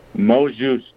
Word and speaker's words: mot juste: Mot juste. – Oh, mot juste. Oh. – So it's spelled M-O-T space J-U-S-T-E.